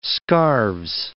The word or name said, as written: scarves